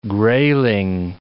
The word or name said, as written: grayling